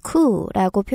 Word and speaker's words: coup: 영어에서는 주로 줄여서 앞에만 쿠라고 표현을 하는데요. 스펠링은 C O U P라서 마치 쿱처럼 보이지만 발음할 때 P 사운드가 안 나고요. 쿠라고 발음합니다.